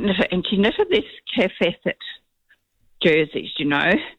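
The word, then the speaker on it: Kaffe Fassett: Kefacet. – Yeah, yeah. So it's spelled I just wanted to, for everyone, K-A-F-F-E, Kafe, Facet, F-A-S-S-E-T-T.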